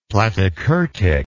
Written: platykurtic

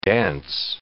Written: dance

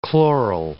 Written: chloral

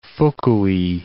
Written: Fukui

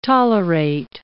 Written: tolerate

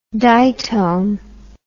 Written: ditone

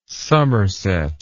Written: somerset

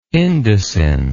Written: indocin

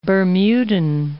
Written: Bermudan

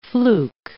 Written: fluke

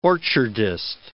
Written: orchardist

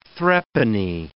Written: threepenny

